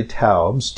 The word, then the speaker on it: Taubes: And for our listeners who are not familiar with Gary Taubes, T-A-U-B-E-S, the E-S is not pronounced, there was an article back in 2002.